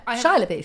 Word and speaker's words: Shia LaBeouf: Then there was some barbed comments between Shia LaBeouf. Is that how you say his name? – Yeah, Shia LaBeouf.